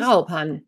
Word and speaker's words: Graupan: Ihr, ja, wer ist Grauban oder wie spricht man den aus? – Graupan, ja, genau.